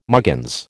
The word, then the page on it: muggins: American English